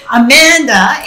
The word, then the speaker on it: Amanda: Amanda, A-M-A-N-D-A, and then my last name is Colucci, C-O-L-L-U-C-C-I.